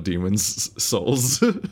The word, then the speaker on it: Demon's Souls: It's so frustrating that it's called Demon's Souls. You have to have a distinct pause in order to actually say it.